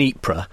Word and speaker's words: Ypres: The Wipers Times, is because it was in Ypres, and no British troops, obviously, could pronounce Ypres properly, so it was known as Wipers.